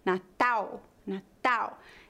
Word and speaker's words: Natal: Outro detalhe, a letra L em final de sílabas é pronunciada como U. Em português brasileiro, não há pronúncia do L. Natal, Natal. Eu escrevi nas legendas com a letra U só para mostrar a pronúncia correta, mas você deve escrever com L.